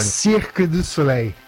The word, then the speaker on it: Cirque du Soleil: qual que é a pronúncia correta em francês, você que esteve lá durante muito tempo? Fala pertinho do microfone. – Cirque du Soleil. – Ah, eu falei certo. – Cirque du Soleil. – Cadê o VAR? Perdão, monsieur. – Cirque du Soleil. Bom.